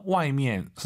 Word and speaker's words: 外面: 外面, 선생님, 근데 이 미엔을 경성으로 해서 外面 어때요? – 也可以的. – 선생님께서는 어떻게 보통 발음하세요? – 外面. – 경성 같은데요, 약간 外面. 네. 제가 드리고 싶은 말씀은 다 된다고요.